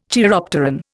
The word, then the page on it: chiropteran: Indian